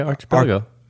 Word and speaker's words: archipelago: Archipelago? – Pretty good, yeah. Archipelago. – Say it again. – Archipelago? – Well, they told me don't say CH.